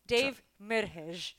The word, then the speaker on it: Dave Merheje: But it's Dave Merheje. – Yes. – Did yes. – I say it right? – Yeah.